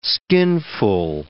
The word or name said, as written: skinful